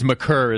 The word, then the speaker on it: Maker: There has been some debate, but my understanding is McCurr is the correct pronunciation.